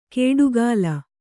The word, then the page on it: ಕೇಡುಗಾಲ: kēḍugāla